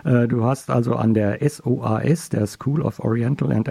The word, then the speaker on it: SOAS: Also die SOAS, das ist, so wird die Abkürzung ausgesprochen,